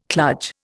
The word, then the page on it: kludge: Indian